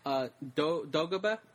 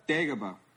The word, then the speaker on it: Dagobah: Look, when they're visiting Yoda, I mean, when Luke is visiting Yoda, when he's on the planet Dagobah? – Dagobah. – Oh, sorry. I didn't say it right. wait, how's it pronounced? – Dagobah.